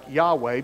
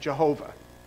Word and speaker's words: Yahweh: It's actually, the Hebrews pronounce it more like Yahweh, but in Germany, the Y has a J sound, or vice versa. So we pronounce it Jehovah.